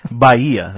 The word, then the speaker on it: Bahia: Bahia. That's how you say it in Portuguese.